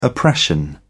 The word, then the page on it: oppression: British English